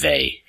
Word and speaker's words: Weil: I would have mentioned the podcast earlier, but I'm never sure how to pronounce her last name. I'm pretty sure it's Weil, again, W-E-I-L, a brilliant Jewish woman who loved the Catholic Church but never entered, and then she died young.